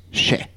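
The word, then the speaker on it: še: Et si l'épisode sort en février, eh ben je peux vous dire que le mot orge en sumérien se prononce chè.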